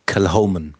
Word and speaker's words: Kilchoman: Also es schreibt man K-I-L-C-H-O-M-A-N. Spricht Kiloman. – Bist du, abgesehen von der Betonung, schon mal sehr richtig? – Wie ist die Betonung? – Also, jetzt ist die Frage, ob ich richtig liege, aber Kilhoman. – Kilhoman. Also das H spricht man doch. – Ja, und das I würde ich wie ein E aussprechen. – Kel? – Das H wird dann, also das C wird verschluckt. – Mhm. – Und dann Kilhoman.